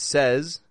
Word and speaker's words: says: However, we want to pronounce it a little differently from how it's spelled. Say, says. Repeat after me. You say, he says, I said so. You hear that there? You say, he says, I said so. The word says, S-A-Y-S, gets that sound, says. And then S-A-I-D.